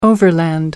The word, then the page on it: overland: American English